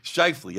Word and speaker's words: Scheifele: You had a Scheifele just crushed Lewis. – Scheifele.